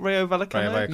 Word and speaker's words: Rayo Vallecano: Is it Rayo Vallecano? – Yeah.